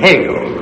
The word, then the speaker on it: Hegel: you'd surely say Hegel, whose name rhymes with bagel.